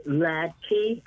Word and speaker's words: Hladky: We have vice chair of that organization, Mary Hladky.